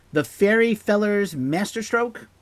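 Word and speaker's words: The Fairy Feller's Master-Stroke: The next song is a bit of a controversial track, The Fairy Fellers Masterstroke, with the hyphen, you gotta pronounce it as kind of a hyphenated word, completely written by Freddie.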